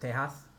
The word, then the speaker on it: Tejas: It's actually pronounced Tejas.